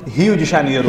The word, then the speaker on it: Rio de Janeiro: Rio de Janeiro. – Rio, because the R is with the H.